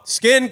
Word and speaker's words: Skyn: Because it's spelled S-K-Y-N. – Yeah. – Dude. – That's good. – Yo, Skin Condoms.